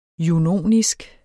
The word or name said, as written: junonisk